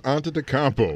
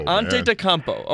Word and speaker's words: Antetokounmpo: It's right, Giannis, good grief, Antetokounmpo. – Antetokounmpo.